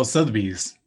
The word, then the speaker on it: Sotheby's: Sotheby's? – Oh, Sotheby's.